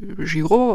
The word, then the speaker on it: Giro: Im Italienischen spricht man Giro wie Giro aus und es bedeutet sowas wie Kreis oder Umlauf.